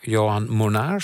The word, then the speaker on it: Johan Monaar: Johan Monaar, spreek ik dat goed uit? – Ja, spreek je heel goed uit.